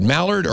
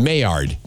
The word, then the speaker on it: Maillard: How would you say this word? Mallard or Maillard? Maillard. M-A-I. – I can't. – L-L-A-R-D. – I don't know. – Maillard.